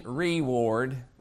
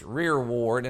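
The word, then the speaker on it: rearward: I know I've always pronounced that wrong, but I always pronounce it reward, but it's rear ward, and I'll explain what that is in a little bit.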